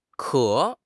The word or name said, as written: ke3